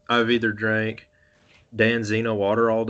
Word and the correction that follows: Dasani: Dasani? – Yeah, you Dasani.